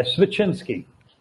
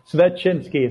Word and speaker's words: Svechinsky: Yeah, Svechinsky. – Svechinsky? – No, Svechinsky. It's one of those hard last names to pronounce.